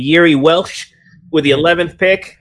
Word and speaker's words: Jiří: Is that how you pronounce it? Jiří? J-I-R-I? – I don't know. I just like saying Procházka. All right. – It is.